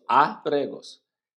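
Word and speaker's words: ábregos: Abregos, abregos. Ya te vías por los abregos. – No, no. El acento es en la A. Entonces es esa que tienes que pronunciar más fuerte. Entonces es abregos.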